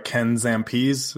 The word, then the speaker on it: Zampese: Ken Zampese, is that it? – Yes, that is it.